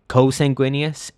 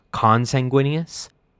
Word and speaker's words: consanguineous: I noticed that in our last episode, I have been consistently saying co-sanguineous instead of consanguineous.